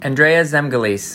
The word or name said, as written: Zemgulys